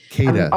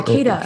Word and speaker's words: KEDA: Yeah, Kata. – Kata, how do you spell that? That's one I hadn't heard yet. – Yeah, KEDA, Kubernetes Event Driven Architecture.